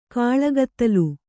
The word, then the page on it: ಕಾಳ್ಕತ್ತಲೆ: kāḷkattale